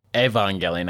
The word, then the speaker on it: Evangelion: The end of Evangelion is like, like Evangelion, I guess, to say it correctly.